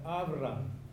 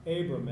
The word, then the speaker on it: Avram: And here initially, his name was Avram, or Abram, as we would pronounce it in English.